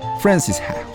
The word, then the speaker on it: Frances Ha: Frances Ha, sería más bien la pronunciación. Frances Ha.